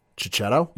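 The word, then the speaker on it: Checchetto: And with Marco, and I'm going to butcher this last name, Cicchetto? – Yep, you got it.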